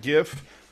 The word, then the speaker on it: GIF: It's a hard G. Hard G. – I know, but I keep saying GIF by accident.